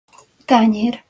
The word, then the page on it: tanyer: Hungarian